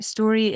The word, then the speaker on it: Storie: So my business name is A Design Story, and Story is my last name, so it's spelt with a IE on the end.